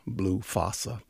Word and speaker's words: Fassa: Blue Fassa obviously rhymes with Blue Bossa,